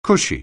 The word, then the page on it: cushy: anglais